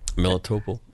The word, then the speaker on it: Melitopol: Right, Melitopol, and again, you've heard of Sevastopol. This is Melitopol.